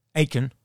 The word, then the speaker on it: Aitken: Aitken, I can never say it right. Stock? – Aitken.